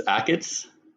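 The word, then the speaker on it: Achatz: But how do you say his surname, by the way? Because he's got a cat. – I think it's Akits.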